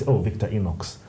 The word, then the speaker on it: Victorinox: Victorinox.